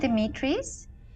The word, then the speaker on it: Dimitris: Do I say your name, Dimitris? – Yes. You know, everyone asks me about this.